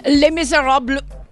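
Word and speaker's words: Les Misérables: Les Miserables. – That's exactly how it's pronounced.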